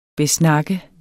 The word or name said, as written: besnakke